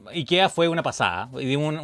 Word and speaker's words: Ikea: Entonces, ahí dice IKEA, no es IKEA. No, no es IKEA. – es Ikea. Es Ikea. sí.